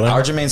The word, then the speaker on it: Aljamain: Aljamain Sterling.